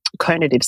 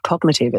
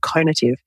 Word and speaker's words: conative: It's also a great example of my conative style. And I didn't mispronounce that for those listening. It's not cognitive. It's a thing called conative style, and it's well worth Googling if you have a spare minute.